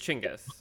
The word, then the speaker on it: Chinggis: Well, technically it's Chinggis. – Genghis or is it Genghis? – It's Genghis Khan. It's Chinggis with CH is how you would pronounce it.